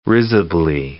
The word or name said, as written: risibly